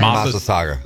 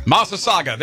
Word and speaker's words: Massasauga: Massasauga. – Massasauga.